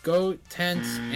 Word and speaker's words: Goten: I can't pronounce his name. I'm seeing G-O-T-E-N-K? – No. – Man, I'm just blanking on it. – Goten. – I thought I said Goten. Shit, I'm not saying it right. Alright, Gotenks and Trunks are... – No, you did it again. Goten. Goten. – Alright, the last no part... No S, is... no K.